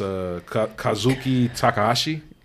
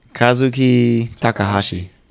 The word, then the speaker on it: Kazuki Takahashi: I know it's Kazuki Takahashi. – Kazuki Takahashi.